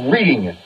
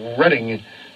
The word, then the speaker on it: Reading: Yep, presented by the Muhlenberg Elementary Center and Reading Pediatrics. Good citizens of the Reading capital of the world, Reading, Pennsylvania. See, they're both spelled the same way. That's why you figured that out on your own.